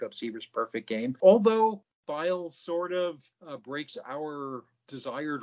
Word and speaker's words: Pfeil: So coming up during that season was a young man named Bobby Feil, P-F-E-I-L. The P is silent, but his presence was not.